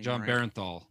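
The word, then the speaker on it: Jon Bernthal: John Bernthal.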